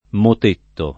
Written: motetto

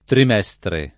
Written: trimestre

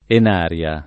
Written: Enaria

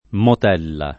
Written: motella

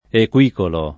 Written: equicolo

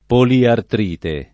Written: poliartrite

